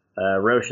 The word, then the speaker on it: Roesch: Roesch is spelled R-O-E-S-C-H.